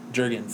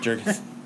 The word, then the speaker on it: Jurgens: Yeah, Dan Jurga writes it. – Jurga? – Huh? – Jurga? – Is that how you say it? How do you say it? Jurgens, sorry. – Oh. – Jurgens.